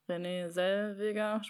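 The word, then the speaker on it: Renée Zellweger: Ich mag zwar René Zellweger, spricht man sie so aus? Ja.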